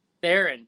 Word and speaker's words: Theron: I always take th- Therian as is it Therian? Did you say Therian? I take Therian as like French. – It's Theron. – Theron.